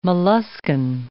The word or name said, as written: molluskan